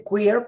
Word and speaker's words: queer: Mira, es la primera vez que escucho la palabra, en inglés se dice queer, pero se escribe q u e r Así es.